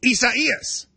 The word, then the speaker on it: Isaias: It looks like Isaiah, but without the H. – Spell it. Can you spell it for me? – I-S-A-I-A-S. – And it's four syllables. I do know that. I-S-A-I-A-S. And it's like... – I-S-A-I-A-S. – Isaias. I think that's how it is. – No. – Yes, it is. – I think it's Isaias.